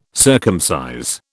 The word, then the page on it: circumcise: British English